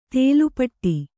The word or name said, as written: ಥಾಲೀಪಿಟ್ಟು